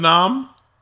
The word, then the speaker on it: Memnon: Oh, King Memnon. Memnon. Memnon. Memnon. M e m n o n.